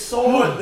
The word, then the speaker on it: sword: The word sword... has a W in it correctly, but it is sword. The W is silent, motherfucker.